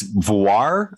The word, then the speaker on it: voir: That's It's voir, I think. V-O-I-R, voir.